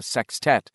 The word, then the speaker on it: sextet: Richie had, in fact, been nearly convinced that they wouldn't see Stan again and the Losers Club would become a sextet, a word Richie liked a lot, always with the emphasis on the first syllable.